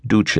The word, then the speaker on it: Duce: In Italian, the word is pronounced Duce, as in Il Duce, which was Mussolini's nickname and means leader.